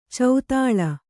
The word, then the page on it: ಚದಲು: kannada